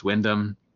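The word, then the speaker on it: Wymondham: On the train in Norwich, there was a place that if you read the name, it looks like it's spelled Wimmendum, mm-hmm. and it's just pronounced Wyndham. They take out M-O-N when you say it.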